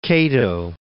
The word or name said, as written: Cato